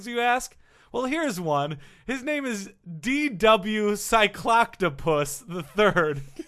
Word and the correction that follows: Cycloptopus: Cycloptopus. Yes, I pronounced it right.